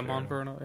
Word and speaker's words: Monferno: Monferno. Is that how you say it? – Yeah, Monferno, yeah.